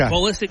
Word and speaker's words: Pulisic: We have a star named Christian Pulisic that everybody loves. We have a guy named... – Say that again. – Christian Pulisic. – Pulisic? Is that how you say that? – Yeah. – Okay. – Pulisic.